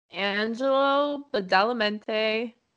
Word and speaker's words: Angelo Badalamenti: Angelo Badalamenti is, I think I said that right.